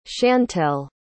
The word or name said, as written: schantelle